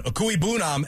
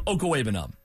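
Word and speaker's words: Okwuegbunam: He ought to say, after he calls a big Alberto touchdown, Okoye Bunam, and yes. – Up yours. – Yes, folks, it is pronounced that way. – Okoye Bunam. – Okay. So we're wrong. – We are wrong.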